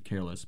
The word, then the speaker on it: CRLSS: I think you pronounce it careless, but C R L S S.